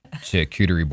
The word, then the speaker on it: charcuterie: If I put like especially if I put a chicken foot or a duck neck, like I kind of make a charcuterie board.